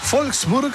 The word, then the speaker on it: Wolfsburg: Saludamos a Siegfried desde Wolfsburg, Alemania. No, ese Wolfsburg, porque la W se pronuncia como V corta, ¿no? O V, ¿no? Se dice V.